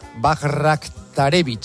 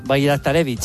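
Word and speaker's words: Bajraktarević: El primero es un bosnio del PSV que se llama, voy a decirlo tal y como se escribe, Esmir Bajraktarevic. – Sí, señor, Bajraktarevic. 20 años del PSV, zurdo.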